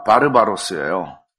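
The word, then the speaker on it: 바르바로스예요 (βάρβαρος): 여기 그 원주민이라고 번역된 말을 헬라어로 어떻게 읽는가 하면 헬라어로 발음이 그렇습니다. 바르바로스예요.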